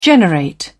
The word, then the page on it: generate: British English